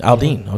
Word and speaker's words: Aldean: Aldean.